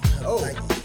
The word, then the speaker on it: Hawaii: The W is like a B. Hawaii.